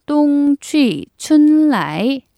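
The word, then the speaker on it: 똥, 취, 춘, 라이 (冬去春来): 중국어로 겨울은 겨울 동자를 써서 똥이고요. 가다는 취, 봄은 춘. 이건 정말 봄 춘자의 발음 거의 그대로네요. 자, 오다는 라이입니다. 자, 이걸 다 이어붙여서 똥, 취, 춘, 라이 라고 하시면 됩니다.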